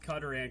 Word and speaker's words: Qatar: Technically, both work. Technically, both Qatar and Qatar.